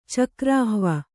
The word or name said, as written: ಚಕ್ರಾಹ್ವ